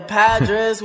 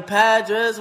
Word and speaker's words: Padres: oh, yeah, the Padres won. If you're saying this wrong, your team got smoked. Yeah, yeah. That's right. The Padres won.